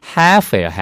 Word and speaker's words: half: H-A-L-F 할 때 L은 묵음이니까 절대 half 아니고 half예요, half.